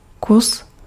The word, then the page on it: morceau: [mɔʁ.so]; France